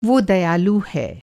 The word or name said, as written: दयालु